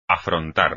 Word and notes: afrontar: espagnol

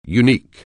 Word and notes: unique: anglais

